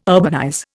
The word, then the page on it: urbanize: Indian